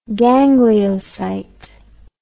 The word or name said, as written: gangliocyte